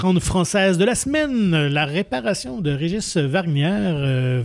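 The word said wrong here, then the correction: Wargnier: Vernier. – Comme ça qu'on le dit. – Je pense que Vernier, oui. – C'est un W qu'on prononce comme un V.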